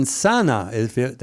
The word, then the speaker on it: Sana'a: But there are apparently commanders and battalions who are now showing up in Sana'a, that's how you pronounce it, where the Ansar Allah is based. – That is correct.